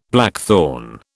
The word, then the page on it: blackthorn: British English